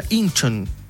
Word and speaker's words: Incheon: Se pronuncia Incheon.